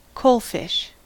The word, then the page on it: coalfish: American English